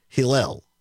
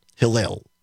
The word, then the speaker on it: Hillel: it is pronounced Hillel. Hillel. So I apologize for the mispronunciation on that.